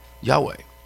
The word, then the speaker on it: Yahweh: It's YHWH. We pronounce that as what? Yahweh. Yahweh. It was written that way because the Jews didn't believe you should be able to pronounce the name of God, so they didn't put any vowels with it.